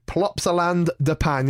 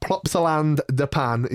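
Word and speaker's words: Plopsaland De Panne: We're now going to move to Plopsaland de Pan. Yes, I did say that right. – I already love the name of this park in Belgium. We're going to talk about Plopsaland de Pan.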